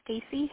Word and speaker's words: Stacey: This is Stacy Hippert.